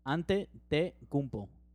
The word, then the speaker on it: Antetokounmpo: You got to pronounce it in Spanish. Antetokounmpo.